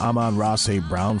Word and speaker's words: Amon-Ra St. Brown: How do you say this guy's name? Is it Amon-Ra St. Brown for the Lions? – Nicely done.